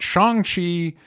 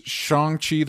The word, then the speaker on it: Shang-Chi: I'm going to start referring to it as Shang-Chi. The coverage I saw said that I guess is the correct way to pronounce it. Roxy, you've heard it the way I was pronouncing it is correct. Shang-Chi.